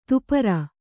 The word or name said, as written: ದಪ್ಪರಿ